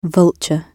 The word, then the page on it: vulture: British English